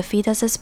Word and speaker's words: fetus: Based on the fetus's position in the womb. 接着我们来看 fetus 这个字。 Fetus 的发音比较特别，这边的 e 发成长母音 e 的声音，然后 tus 的 u 发成耳的声音，那这个字是胎儿的意思。